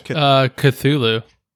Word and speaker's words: Cthulhu: How do you pronounce, like, Cthulhu or whatever it is? – Cthulhu.